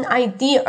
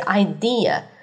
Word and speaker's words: idea: 记住，你千万不要卷舌读成 idea 啊，好难听，idea 不是 idea 是 idea。